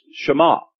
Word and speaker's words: Shema: And so it could possibly be written that way and it would still be pronounced Shema.